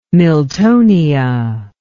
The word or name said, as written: miltonia